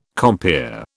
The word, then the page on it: compeer: British English